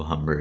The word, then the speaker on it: Humber: Carlos Pena and Jose Veras and Eric Bedard and Phil Humber, and that's about it. – Yeah, the H is silent, actually. – What? Oh, Humber.